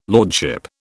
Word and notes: lordship: British English

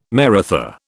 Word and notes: Maratha: British English

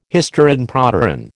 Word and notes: hysteron proteron: American English

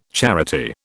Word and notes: charity: British English

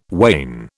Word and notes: wane: British English

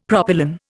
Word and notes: propylon: Indian

